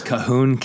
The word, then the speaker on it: Colquhoun: If you're a Scottish speaker, you know it's Cahoon, K-A-H-O-O-N, phonetically. But how do you spell it for us Westerners? – C-O-L-Q-U-H-O-U-N.